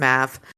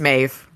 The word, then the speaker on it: Maeve: And her name is Maeve, even though in the audiobook it is pronounced as Mav. It's Maeve. It's an Irish name.